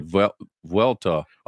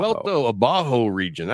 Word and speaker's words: Vuelta Abajo: It's the Vuelta. – I would call it Vuelta Abajo. Vuelta Abajo region. I think that probably sounds way better than mine.